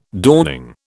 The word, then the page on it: dawning: British English